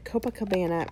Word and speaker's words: Copacabana: But Brazilian Bum Bum Cream, Copacabana, I said it right, Copacabana Bronze Glow Oil.